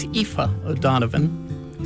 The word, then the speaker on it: Aoife O'Donovan: By the way, before I mispronounced Ms. Donovan's name, it's Aoife O'Donovan, Sarah Watkins, and Sarah Jarosz, all part of the group.